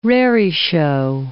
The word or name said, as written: raree-show